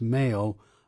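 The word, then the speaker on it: M(h)aol: That's M apostrophe bracket H close bracket AOL, pronounced Mail.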